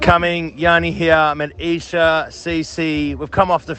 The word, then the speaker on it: Esher: I believe it's pronounced Esher.